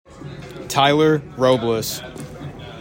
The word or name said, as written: TYLER ROBLES